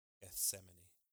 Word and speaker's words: Gethsemane: Say it for me one time, brother, so I can hear you say it. Gethsemane. I'm missing that th.